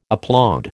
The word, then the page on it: applaud: American English